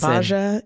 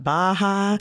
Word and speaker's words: Bahja: It's Bahja. It's not Baja. It's not hard.